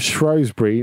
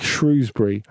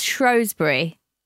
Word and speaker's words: Shrewsbury: Andy is not posh, but is correct in the pronunciation of Shrewsbury, not the phonetic Shrewsbury. But don't worry, Jules, it's a common mistake folks not from Shropshire make.